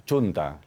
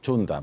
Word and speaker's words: Xunta: Perdón, que me dicen que lo he dicho mal, que es un... No, Xunta, lo he dicho mal. Xunta, perdón. Pido disculpas, ¿eh? – Lo has dicho con la X galega.